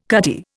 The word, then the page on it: cutty: Indian